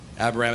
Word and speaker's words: Abiram: Good job. Abiram. That's in Numbers 16, verse 1. Sorry, what? I pronounce it the same way that he did, Brother Trevor. Brother Trevor and I argue over word pronunciations all the time, so I'm with our brother on the pronunciation.